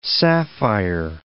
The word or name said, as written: sapphire